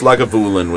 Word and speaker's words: Lagavulin: Lagavulin, like a javelin. – Lagavulin. Lagavulin. – I think it's Lagavulin. – I like Lagavulin. – I think it's Lagavulin with the emphasis on the third syllable.